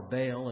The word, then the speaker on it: Baal: Chemosh was one of them, and Baal, or Baal, as many of us pronounce his name.